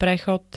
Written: prechod

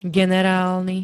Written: generálny

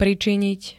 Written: pričiniť

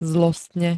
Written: zlostne

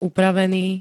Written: upravený

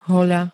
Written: hoľa

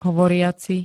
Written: hovoriaci

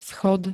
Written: schod

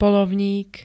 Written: poľovník

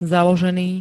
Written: založený

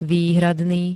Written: výhradný

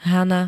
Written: Hana

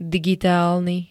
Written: digitalny

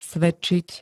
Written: svedčiť

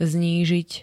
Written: znížiť

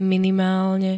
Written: minimálne

